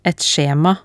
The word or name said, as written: et skjema